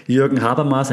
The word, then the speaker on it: Jürgen Habermas: Jürgen Habermas, and yes, that's how you pronounce the name, trusted German, that has expanded beyond, like, coffee houses, what it was like back then, right, into, like, digital domain.